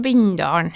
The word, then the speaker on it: Bindalen: Du er jo opprinnelig fra Sør-Helgeland og en eh, plass eller kommune som heter Bindal. Eller du uttaler det sikkert sånn, hvordan vi, sier du det? – Jeg er fra Bindalen.